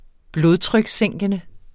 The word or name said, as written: blodtrykssænkende